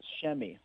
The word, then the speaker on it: Shemi: His name is actually pronounced Shemi, like S-H-E-M-I. – Thank you for that. – Yeah, Shemi Ojele is how you say it.